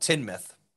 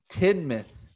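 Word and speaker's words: Tynemouth: It's called Tinmouth. – Tinmouth. – T-I-N-M-I-T-H.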